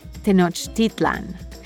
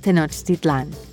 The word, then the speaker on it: Tenochtitlan: There are two accepted ways to pronounce the name of the ancient city of the Aztecs. You'll often hear Mexican historians say it with the accent on the I, Tenochtitlan. But today we'll be using the common pronunciation that puts the accent on the last syllable, Tenochtitlan.